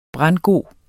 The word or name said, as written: brandgod